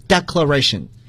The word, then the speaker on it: declaration: 大家你注意，它的动词呢来自于 declare，你会发现动词和名词元音的读音是发生变化的。 declare， declaration，就像大家学过 explain， explanation 是一样的。